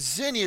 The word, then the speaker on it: xenia: So the Greek word for hospitality is actually, it's a X-E-N-I-A. It's pronounced xenia.